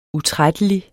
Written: utrættelig

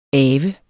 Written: ave

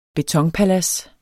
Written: betonpalads

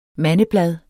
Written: mandeblad

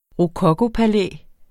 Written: rokokopalæ

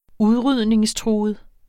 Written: udrydningstruet

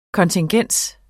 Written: kontingens

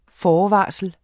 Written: FORVARSLET

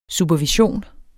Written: supervision

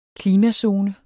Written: klimazone

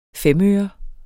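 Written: femøre

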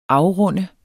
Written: afrunde

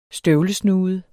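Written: STØVLESNUDER

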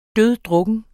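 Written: DØDDRUKKEN

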